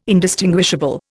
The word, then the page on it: indistinguishable: Indian